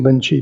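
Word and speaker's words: Linji: Rinzai, when these teachings from Lin Chi came to Japan, the Japanese have their own way of pronouncing characters, the Chinese characters. So they, in their way of pronouncing, Lin Chi became Rinzai, but it's the same person.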